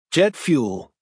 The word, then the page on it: jet fuel: American English